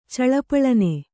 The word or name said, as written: ಚಳಪಳನೆ